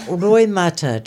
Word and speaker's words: roimata: What about roimata? I say that right? – Roimata.